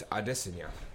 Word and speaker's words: Adesanya: Yeah, and we've been saying his name wrong, apparently. He's been actually pretty adamant about it. It's Adesanya.